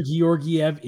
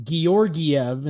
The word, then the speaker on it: Georgiev: Alexander Georgiev is in. – Georgie. – And it bothers me because it's his nickname is Georgie, but his actual name is pronounced Georgiev.